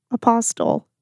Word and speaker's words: apostle: You really pronounced that T. – Danny, get this out of the episode. – It sounded like a pasta dish. – Apostle? – Apostle. – How do you say Apostle. – No. – The T is silent.